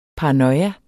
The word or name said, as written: paranoia